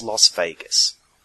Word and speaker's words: Las Vegas: It's Las Vegas. Vague. Plague. Craig. That's how it's said.